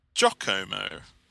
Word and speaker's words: Giacomo: And he said, "Esteemed fellows of the WFD pod, Giacomo is pronounced Giacomo and is Italian form of Jacob.